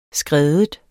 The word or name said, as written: skred